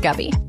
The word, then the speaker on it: Gabby: Yeah, it's spelled Gabby, but it's pronounced Gubby.